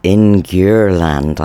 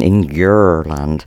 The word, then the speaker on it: Ingurland: That is Karl Philips and the rejects with Ingurland. I say, I don't know if I say that right, a bit Bristol on that one, Ingurland. Uh, it's I N G U R L A N D, Ingurland.